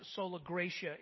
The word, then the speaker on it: sola gratia: And sola gratia, or sometimes sola gratia, it's pronounced. And the reason why we have different ways of pronouncing it is because nobody speaks Latin anymore.